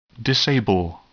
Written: disable

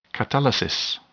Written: catalysis

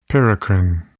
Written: paracrine

